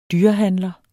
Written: dyrehandler

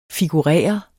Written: figurere

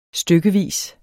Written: stykkevis

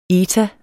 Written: eta

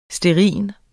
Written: stearin

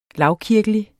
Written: lavkirkelig

